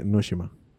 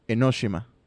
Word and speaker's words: Enoshima: Tú que sabes japonés, dime. Eh, eh, debería ser eno, eh, Enoshima. Enoshima. Bueno, la acentuación no importa tanto, sino más que nada qué letras y qué, qué vocales y qué no vocales se pronuncian.